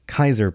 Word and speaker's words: Kaiser: I think what he's saying here is Kaiser has, I always want to read it Caesar, but it's Kaiser pronounced in the game.